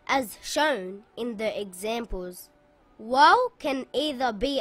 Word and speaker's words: Waw: This letter is called Waw.